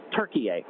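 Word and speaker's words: Türkiye: Oh, well, you know, most of us will spell it like we spell the word turkey, like Thanksgiving turkey, but it's actually now they've asked to go back to their sort of original T-U-R-K-I-Y-E. Turkey-A is the way they pronounce it.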